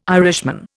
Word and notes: Irishman: Indian

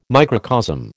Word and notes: microcosm: American English

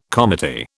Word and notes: comity: British English

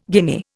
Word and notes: guinea: Indian

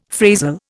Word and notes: phrasal: Indian

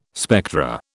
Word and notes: spectra: British English

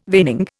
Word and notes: veining: Indian

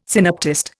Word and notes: synoptist: Indian

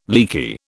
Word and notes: leaky: British English

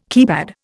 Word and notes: keypad: Indian

